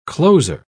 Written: closer

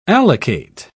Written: allocate

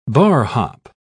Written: barhop

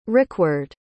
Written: rikward

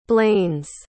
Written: blains

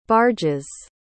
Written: barges

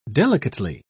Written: delicately

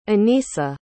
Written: annisa

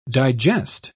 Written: digest

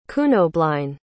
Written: cunobeline

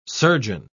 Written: surgeon

